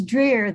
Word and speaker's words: Dreher: That's close. It's Dreher. The H is silent.